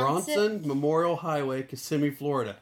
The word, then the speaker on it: Kissimmee: Is it Kissimmee? Did I say it wrong? – Yeah. – I said Orlando. How do you say? No, it's Kissimmee.